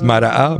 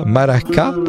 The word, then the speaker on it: Mara'a: It is by the one and only Aziz Mara'a, pronounced Mara'a, Maraka, or Maraga, depends on your dialect there.